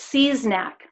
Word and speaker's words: Seasnack: so it's it's Seasnack, like See's candy, and okay. like seize the day, yeah. and then knack, like having a knack at something.